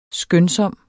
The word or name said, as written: skønsom